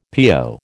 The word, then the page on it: PO: American English